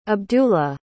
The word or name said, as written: abdulla